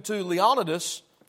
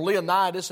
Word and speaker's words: Leonidas: You can go to even further back to Leonidas, or Leonidas, if you want to pronounce that way.